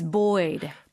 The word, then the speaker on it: buoyed: 이게 buoyed를 당했다 이렇게 쓰려면 buoyed가 아니라 buoyed로 마치 뭐 남자 아이 얘기하는 것처럼 boy처럼 발음이 바뀌어 버리네요.